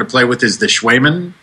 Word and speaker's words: Cwejman: And it's called Schwaemann. – Schwaemann. It's spelled C-W-E-J-M-A-N.